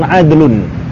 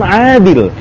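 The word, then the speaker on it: adlun (عدل): Dan adlun bukan adil. Ya, adlun bukan adil. Adlun masdar. Namun bimakna sifat. Ya kan? Jadi kita enggak bacanya dengan bacaan rajulun adlin. Ya, rajulun adlin, tapi rajulun adlun.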